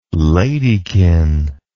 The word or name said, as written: ladykin